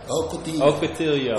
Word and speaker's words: Ocotillo: Is it Octillo? – Ocotillo. Ocotillo. – I'm still working on my Spanish.